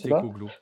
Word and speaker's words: Postecoglou: Alors, oui, ils ont eu euh, euh Postecoglou, ça se prononce Postecoglou.